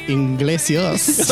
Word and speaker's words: Iglesias: Iglesias.